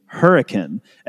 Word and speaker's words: Hurricane: I mean, it's spelled hurricane, like the exact same word that you would say if you were talking about a storm, but it is pronounced in this region hurricane, and no one really knows why.